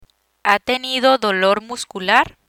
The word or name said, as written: dolor muscular